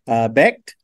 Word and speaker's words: Becht: This is an offense that's led by Rocco, say his last name, Becht, I want to say. Am I saying it wrong? – Like that. I think that's correct.